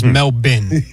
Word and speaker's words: Melbourne: The Australian pronunciation, is Melbourne.